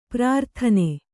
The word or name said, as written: ಪ್ರಾರ್ಥನೆ